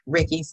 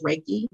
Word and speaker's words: Reiki: Some people say Ricky. I pronounce it as Reiki.